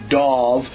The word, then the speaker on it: Dov: See, this is a great way for us to retrain these people how to say my name. – That's right. – That's the bird. It has an E. Mine has no E. It is the Dove. Pronounce the O. – That's right. And it's like of with a D in front of it. And it's got a squiggly thing over the O.